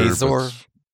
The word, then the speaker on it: Mazor: No, it's a great next generation for, I think it was Mazor is how that was said. And, sorry. – No, it's spelled like Razor. – Mazor.